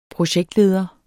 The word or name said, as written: projektleder